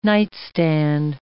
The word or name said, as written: nightstand